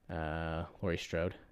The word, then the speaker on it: Laurie Strode: there are a bunch of mannequins that Laurie Strode, is that right? – Yes. – Okay, good. I'm saying it right.